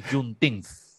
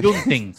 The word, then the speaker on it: Juneteenth: Hoy es el día de Juneteenth. – Con TH al final. – Juneteenth, sí.